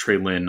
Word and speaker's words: Treylon: So next, I've got the one that I've been I've been raving about Treylon Burks from who I found out is pronounced Traylon, not Traylon.